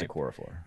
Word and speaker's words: Okorafor: It's just Okorafor.